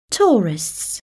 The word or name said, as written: tourists